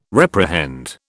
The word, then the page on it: reprehend: British English